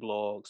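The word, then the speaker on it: Bloggs: Of course, you can find me at all social media under the same name, the THE Wade blog, spelled like it sounds, WADEBLOGGS. Remember, I never go broke. My name got two G's.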